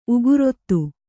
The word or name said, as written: ಉಗುರೊತ್ತು